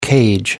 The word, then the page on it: cage: American English